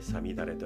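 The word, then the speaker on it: 五月雨: さみだれというのは 5 月の雨と書いてさみだれと読みます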